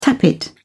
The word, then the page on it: tappet: British English